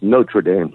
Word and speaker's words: Notre Dame: And the only time Leahy actually got a little upset with Beano was because the way he was pronouncing Notre Dame. He said, "Our Lady School is pronounced Notre Dame, lad." And Beano said from that point forward, after Leahy corrected him, he started calling it Notre Dame.